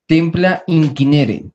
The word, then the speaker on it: Templa Incinere: Templa Inquinere se llama. Templa Inquinere, se escribe Templa Incinere.